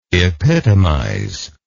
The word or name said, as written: epitomiser